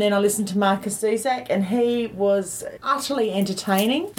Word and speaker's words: Zusak: So I went Monday night after work, and I was lucky enough to hear Martin Zusak. I hope I'm pronouncing his name correctly. – Zusak, yes.